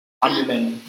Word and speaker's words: Amilyn: I think you were putting another syllable in there. – Amelie.